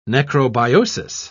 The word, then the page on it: necrobiosis: British English